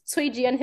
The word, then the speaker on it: Cui Jian: By Kui Jiang. – Yes, so the C would be pronounced like a ts, t so so Cui Jian is how we would pronounce that his name.